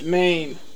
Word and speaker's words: mein: Is that Main Street? – It's pronounced Maine, like chicken lo mein. M-E-I-N, Maine.